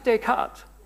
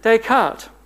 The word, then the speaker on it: Descartes: Have I pronounced it right? Not? Just Descartes. Thank you. Come up here and I've got a few other words. Descartes.